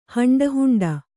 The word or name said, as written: ಹಂಡಹುಂಡ